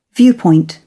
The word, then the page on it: viewpoint: British English